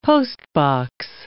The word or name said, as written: postbox